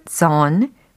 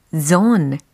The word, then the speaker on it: zone: 이처럼 comfort zone, comfort zone 발음 연습도 충분히 해보세요. 존이 아닙니다. zone, zone 이렇게 뭔가 쫀쫀한 발음이 들리게 되어 있죠.